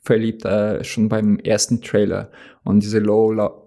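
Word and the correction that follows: Lorde: äh, wie wird das, äh, die Band ausgesprochen? Low, Lore. Ähm, mit diesem Song fand ich irgendwie mega geil. Ich dachte mir. – Ach, du meinst Lord? – Wie? – Meinst du Lord, also die Sängerin?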